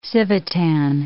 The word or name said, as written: Civitan